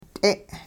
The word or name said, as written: t'e'